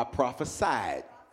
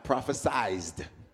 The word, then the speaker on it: prophesied: No, let's com- pronounce it right. So I prophesied not prophesized.